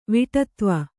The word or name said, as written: ವಿಟತ್ವ